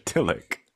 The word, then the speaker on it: Tillich: So to Mr. Tillich, which rhymes with Joe Willick, might I add, to Mr. Tillich, the recognition of this anxiety and not letting it determine the course of your life, that's what he proposes as? – I mean, I think...